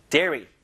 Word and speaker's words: dairy: Dairy. 그죠? 다이어리 아니에요, 여러분. Dairy products. Dairy products. 이거 발음 잘해야 돼요.